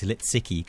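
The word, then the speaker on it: Lisicki: I just read this article that said that she's German and it's Zabina with a Z and it's Litsicki because it's obviously a Polish name in origin or something like that.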